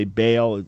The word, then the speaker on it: Baal: And that has gods called El and Baal. Many would say Baal, the English pronunciation in the Old Testament.